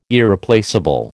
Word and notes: Irreplaceable: American English

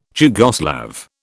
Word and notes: Jugoslav: British English

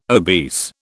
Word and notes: obese: British English

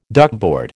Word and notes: duckboard: American English

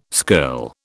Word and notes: skirl: British English

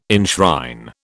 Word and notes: enshrine: British English

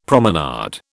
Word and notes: promenade: British English

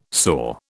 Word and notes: soar: British English

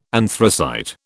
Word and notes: anthracite: British English

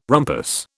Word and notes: rumpus: British English

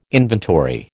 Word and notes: inventory: American English

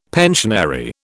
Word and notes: pensionary: British English

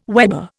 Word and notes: weber: Indian

